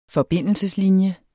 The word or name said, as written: forbindelseslinje